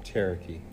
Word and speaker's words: Cherokee: Cherokee.